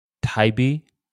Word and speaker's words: Taibbi: There's a guy named, let me make sure I pronounce his last name right Matt Taibbi, T A I B B I.